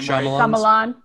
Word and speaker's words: Shyamalan: Shyamalan. – Yes, thank you. I always like, and back when I was like in high school, for whatever reason, I wanted to call him M. Night Shyamalan Man.